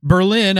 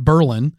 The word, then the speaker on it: Berlin: Just like there's Berlin up by the lake, but they call that Berlin.